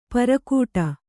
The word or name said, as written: ಪೆರ್ಗಟ್ಟ